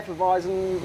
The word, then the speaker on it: Hefeweizen: Yeah, Hefeweizen. – Yeah, it's a Hefeweizen.